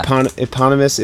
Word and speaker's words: eponymous: Eponymous, if that's how it's pronounced. – Yeah, I think it is, yeah. You got it right.